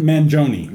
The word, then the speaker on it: Mangione: Mangione? – Mangione.